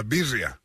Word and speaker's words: birria: Birria? – Birria. – Okay, B-I-R-R-I-A, right? – Yeah.